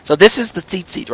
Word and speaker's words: tzitzit: I've spelled it T-Z-I-T-Z-I-T. So, tzitzit. But you can pronounce it as if you're looking at two seats and you just say them back to back rapidly. Seat, seat, or seat, seat. So some people say seat, seat.